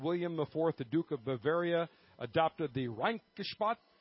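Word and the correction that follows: Reinheitsgebot: I believe I'm pronouncing that correctly. – Rheinhutzgebot.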